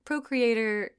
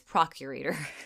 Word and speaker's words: procurator: I was like, procreator, Suetonius, Paulinus. And I was You meant? – And really, it's procurator. – Gotcha. – Which is pronounced completely different and means a completely different thing.